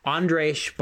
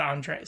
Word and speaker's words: Andrzej: And so you're saying Drej, it's more Drej, like garage. – What? Do you work for him? Andrzej Bartkowiak.